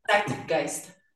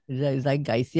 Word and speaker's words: Zeitgeist: And this album, the new, latest one, in clash with the Zeitgeist, Zieggeist. Pronounce that for me again. Zeitgeist. Zeitgeist. Yep.